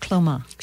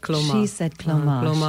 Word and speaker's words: Cleoma: So it is pronounced Cleoma. – She said Cleoma. – She didn't put the little accent in there.